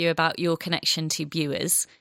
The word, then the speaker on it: Bures: And that's something, if you've come across it, you might not know it's called the White Dragon of Bewers, because Bewers is spelt B-U-R-E-S. Who would imagine to call it Bewers from such a spelling? But that's what Rosie informs me is the correct spelling. – It's Bewers, yes.